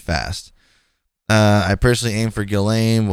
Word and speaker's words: Ghislaine: It said, aim for Shizlane. But Vicky Ward, a journalist who was early to Epstein stories, uses what I think is the right pronunciation, Ghislaine. So Ghislaine. And Ghislaine Maxwell's brother, Ian, says it very casually, like Ghislaine.